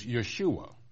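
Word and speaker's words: Yeshua (יֵשׁוּעַ): Actually, in Hebrew, it is Yeshua. Yes, just like that Yeshua, the one we call Jesus Christ.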